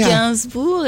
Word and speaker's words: Gainsbourg: Gainsbourg, if you want to pronounce Gainsbourg? – It. The French way. But the real way is the Russian name, which was Ginsburg.